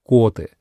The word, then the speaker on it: Коты: Ударение правильно ставить на «о» — Коты.